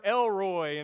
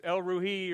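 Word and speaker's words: El Roi: She says, you're Elroy, and that's East Texan pronunciation, but really it's Elruhi or something like that.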